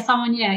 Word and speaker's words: sommelier: I guess it's training as a word I can never say, sommelier, I think is how you say it. – Yeah, sommelier, yep.